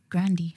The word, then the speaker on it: Grande: Yeah, Grande. That's apparently how you pronounce her name. Grande.